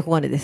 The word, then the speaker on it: 黄金: そして北小金駅周辺、小金イルミネーションといって、小金だけどこれ黄金と書いて小金です。